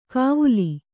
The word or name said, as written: ಗವುಲಿ